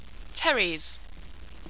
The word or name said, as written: Pteris